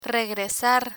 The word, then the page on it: regresar: Spanish